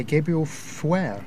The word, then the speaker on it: Fauré: That was In Paradisium by Gabriel Fauré. I believe that's how you pronounce it. Gabriel, I think that is correct.